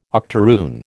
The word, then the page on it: octaroon: American English